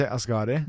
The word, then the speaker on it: Asghari: In Norwegian, I would say Asghari. – But in English, you say A is Gari.